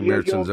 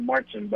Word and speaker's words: Märzen: I had a few Märzens over the weekend. So. – Yeah, you'll find 1.5 million people in the U.S. call it a Marzen, but they're. The German pronunciation is Märzen.